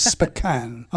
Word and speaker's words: Spokane: They say Spokane over here. The British don't know how to pronounce that. It's Spokane. I was taught that in America.